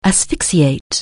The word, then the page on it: asphyxiate: anglais